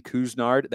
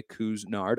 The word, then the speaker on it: Couisnard: I want to talk a little bit more about Jermaine Couisnard. That is how you pronounce his name, by the way, Couisnard.